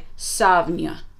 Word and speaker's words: Samhain: And the pronunciation that I've looked up is like Savnia.